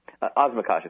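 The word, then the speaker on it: Osmakac: It's the story of a guy named Sammy Osmakak. Is that right? – Osmakash is how it's pronounced.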